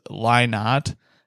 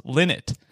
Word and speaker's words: Lynott: Also, I went through my life pronouncing Phil's last name as Lynott until I was corrected by a very polite Irishman who told me it's pronounced Linnet.